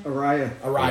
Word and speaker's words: Araya: Araya. – Araya.